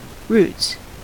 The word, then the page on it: roots: American English